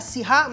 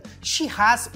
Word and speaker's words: Shiraz: Cabernet Sauvignon, Merlot, Shiraz, é a Sirá, mas eles preferem a pronúncia inglesa, então fica Shiraz, mas é a mesma uva, tá?